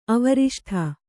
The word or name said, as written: ಅವರಿಷ್ಠ